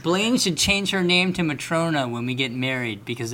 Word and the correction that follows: Matryona: Not Matrona. Matryona. – Matryona. Please mention... – Say it right.